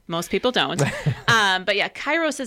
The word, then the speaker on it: Kairos: So it's actually pronounced Kairos. – Kairos Care.